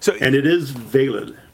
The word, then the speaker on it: Veilid: I have this thing because I've been running Linux for so long that like everything that ends in a D now, you have to pronounce the D. And so it's almost Veilid in my mind.